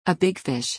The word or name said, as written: a big fish